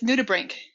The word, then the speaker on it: nudibranch: I pronounce this word nudibranch, but it's not, is it? – It's nudibranch. – Nudibranch. See, this is what happens when you're a voracious reader, but you never actually have to say the word.